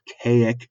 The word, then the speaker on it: Kazik: They fly him to the planet Kaik, which is spelled K-A-Z-I-K, but pronounced Kaik.